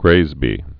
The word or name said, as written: graysbies'